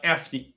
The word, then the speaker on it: Afni: In terms of your business where you're currently at right now, um, and uh, it's pronounced Anfi, right? Uh, Afni. Afni. A-F-N-I.